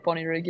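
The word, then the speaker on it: Bonnyrigg: Which, correct me if I'm wrong, Bonnie Rig, is that how you pronounce it? – Yeah, Bonnie Rig, yeah.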